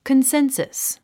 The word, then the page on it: consensus: kənsénsəs; コンセンサス